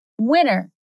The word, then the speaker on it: winter: Mas, claro, a pronúncia cotidiana não é bem assim. É winter. De novo, então esse T é falado bem discretamente. Winter. Essa pronúncia com esse T super rápido, ele é bem típico, principalmente nos Estados Unidos, mas ocorre em outros países também.